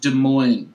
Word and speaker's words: Des Moines: It's pronounced Des Moines. Des Moines.